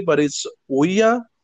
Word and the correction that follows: Ouija: or Ouija. – Ouija board. Yes.